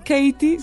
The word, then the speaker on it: Katie: Bueno, Katie se escribe K-A-T-I-E.